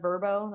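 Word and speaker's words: VRBO: So whenever there's like VRBOs or, which I heard was actually pronounced Verbo, that's new to me, or Airbnbs, I always like getting funky ones where you're up high and looking over.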